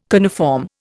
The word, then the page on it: coniform: Indian